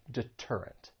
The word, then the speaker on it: deterrent: So when the T comes at the beginning of a stressed syllable, then you're going to say it as a true T and not a flap T. And that is why you would pronounce this and say deterrent.